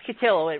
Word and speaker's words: Cotillo: Chris Cotillo? – Yep. – Yes. – Oh, Chris Cotillo. It rhymes with pillow.